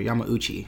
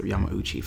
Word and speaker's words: Yamauchi: Iwata took over after the Yamaguchi family, or Yamauchi, I think you pronounce it, Yamauchi family.